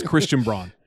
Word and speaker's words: Christian Braun: It's Christian Braun. You spell it Braun. That's not Brown, buddy. That's Braun.